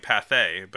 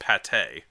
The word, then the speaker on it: Pathé: Made by Méliès and Pathé, which I found out today, we've been calling Pathé, but it's pronounced Pathé.